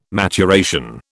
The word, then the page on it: maturation: British English